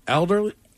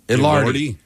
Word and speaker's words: Elordi: Jacob Eldor? – How do you say it? – Elordi.